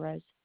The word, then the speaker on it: Rose: Or Marissa Rose. – R-O-S-E? – Uh-huh. – Spell that first name out for me, please. – M-A-R-I-S-S-A.